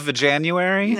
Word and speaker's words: Veganuary: Is that how you pronounce it? – It's called Veganuary.